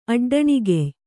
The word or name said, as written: ಅಡ್ಡಣಿಗೆಯ್